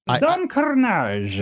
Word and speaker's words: Don Carnage: You have to say it right. – Oh, but I can't. – Don Carnage. – Yeah, I can't roll my R's like that.